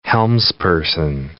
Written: helmsperson

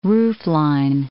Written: roofline

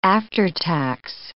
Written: after-tax

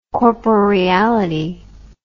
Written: corporeality

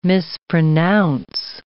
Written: mispronounce

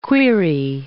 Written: quaere

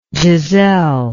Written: Giselle

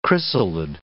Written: chrysalid

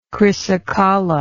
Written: chrysocolla

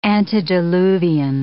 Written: antediluvian

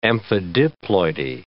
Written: amphidiploidy

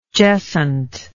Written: jessant

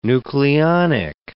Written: nucleonic

